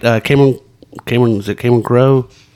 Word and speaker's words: Cameron Crowe: It's a great Cameron Crowe, or am I saying that wrong? – That's right.